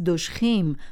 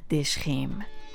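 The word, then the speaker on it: دژخیم: پس دوشخیم درست است، نه دشخیم.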